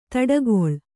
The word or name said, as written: ದಾಟುಗಾಲು